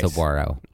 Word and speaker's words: Saguaro: W alliteration. Because it is a W sound. – Right. – Saguaro. – Saguaro. – Not saguaro. – Yeah. – Or saguaro.